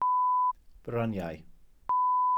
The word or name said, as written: bryniau